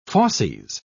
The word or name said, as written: fauces